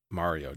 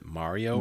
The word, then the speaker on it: Mario: Well, so I think we should be clear that I think the different pronunciations appear in different geographical locations. – Yes, for sure. – I believe the East Coasters are the ones who typically say Mario.